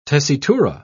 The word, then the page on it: tessitura: British English